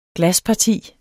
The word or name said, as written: glasparti